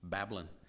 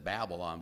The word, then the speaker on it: Babylon: The Tigris is up a little bit farther north, and Ur is basically south of Babylon, as Dr. Sellers always pronounced it, Babylon. We said Babylon, but he said Babylon.